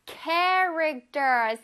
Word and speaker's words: Character: Character. 쌤 입 모양 보세요. Character. Character. 그쵸? 앞에 ch가 있다고 해서 추 소리가 나지 않아? Character. Okay.